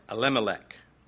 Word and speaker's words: Elimelech: I've heard Elimelech's name pronounced three different ways. El-Elimelech is another way, and the third one I don't remember. I don't really care. Elimelech is what you're going to get from me.